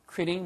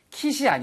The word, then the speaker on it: quit: 예, 발음 주의하세요. 절대 quit이 아니고요. quit. quit. 입술이 나갔다가 들어오셔야 돼요. 그래서 quit. quit. quitting. 쌤, 왜 그렇게 발음해야 되나요? 사전을 찾아보시면 Q 발음 다음에 나오는 모음이 있을 때는요. 거의 99.9% 다 W 발음이 있습니다.